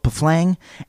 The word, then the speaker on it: Pfleng: The Indians were nearly upset the dugout getting away, and both Pflang and I missed... It's spelled P-F-L-E-N-G. Pflang. Pflang? – Sure.